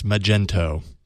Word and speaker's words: Magento: And for example, Coca-Cola and Burger King and many other users leverage the e-commerce platform which Magneto offers. – Is it Magneto or Magento? Or Magento? – You're right. M-A-G-N-E-T-O. I don't know how you pronounce it. – I think if you click through, it's Magento. – Oh, you're right. It's M-A-G-E-N-T-O.